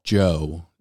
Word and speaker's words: Djo: So Joe Keery, who plays Steve, uh, has a side band called Joe D J O is how he spells it. The D is silent.